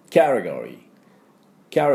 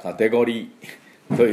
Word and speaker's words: category: キャテゴリー、キャテゴリー、最後 R ですからリーとしっかり出してください。で私の後で発音いきましょう。キャテゴリー、キャテゴリー、はい、これがカテゴリーということになります。